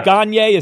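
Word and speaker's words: Gane: So when you say, is it Gagne or Gone? I've heard it pronounced both ways. Are we saying Gagne? – Yeah. – Do I say Gagne? Because he's favored to win here. Do I need to learn how to say Gagne? Is that how it's said? – Yes. Gagne. Okay.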